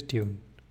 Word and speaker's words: Dune: I'm going to say Dune throughout this book, Dune, because that's how I think it should be pronounced, not Dune, Dune.